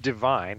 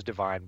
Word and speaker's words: Devine: It's written Devine, and I thought it was Divine, but every announcer I heard on tape says Devin. Uh, so we'll call him Devin.